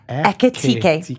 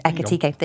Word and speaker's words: Ekitike: The Reds is your first category, and your options were the Isak Etik. Right, so I'm gonna need to say it properly. Ekitike. Ekitike.